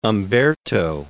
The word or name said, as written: Umberto